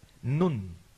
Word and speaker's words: nun: nun, una N, nun, que así se pronuncia esa letra en hebreo.